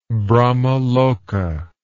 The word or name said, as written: brahmaloka